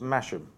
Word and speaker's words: Masham: You can't start throwing silent H's in the middle next to an H. No, not having that. It's Masham Star.